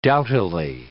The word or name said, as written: doughtily